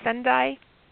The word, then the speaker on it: Sendai: And Sue is one of the founders of the Nozomi Project, which is a handmade jewelry business located near, is it pronounced Sendai, Japan? – Yes.